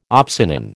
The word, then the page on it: opsonin: American English